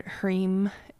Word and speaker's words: Hrim: Which is, and Hrim, you probably know Om is spelled O-M, and Hrim is spelled H-R-I-M, pronounced Hrim.